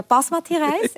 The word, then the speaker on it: Basmati-Reis: Den und ich selbstverständlich falsch betone, weil ich sage Basmati-Reis. – Nein. – Ich habe jetzt gehört, dass man das anders ausspricht. Ja. Das ist wie mein Nachname. – Mhm. – Man muss erste Silbe.